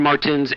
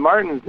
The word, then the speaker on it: Martens: Yeah, you know, a lot of people don't, the Martens is like, it's similar. I mean, a lot of people pronounce their names the same because they put an S on the end of it. It's actually a kind of a silent T. I guess it's German, and that's how they say it, but it's slightly different. – Yeah, it is. I think yours is like T-E-N-S, and mine is T-I-N with an apostrophe S if it's in a plural form.